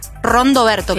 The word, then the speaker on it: Rondoberto: No es que lo digo mal, la banda se llama Rondoberto, claro. Como Don Roberto, ¿sí? – Rondamón.